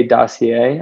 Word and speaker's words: dossier: So I would say Dossier. I think I'm pronouncing that right. POCs are... – That's the French way.